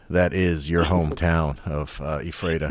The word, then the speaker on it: Ephrata: Ephrata, yeah.